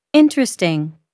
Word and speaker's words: interesting: The vowel immediately after a stressed syllable is sometimes not pronounced. – Average. – Different. – Separate. – Comfortable. – Interesting.